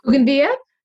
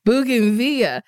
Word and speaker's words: Bougainvillea: I don't think I'm saying them right. Boconavetas. – Mm. – Do you know how to say it? Bougainvillea? Yep. Bougainvillea.